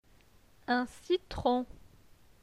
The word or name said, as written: Un citron